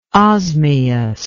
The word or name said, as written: osmious